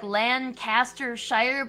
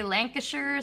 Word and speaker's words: Lancashire: the one is like Lancastershire, but they say Lancashire or something.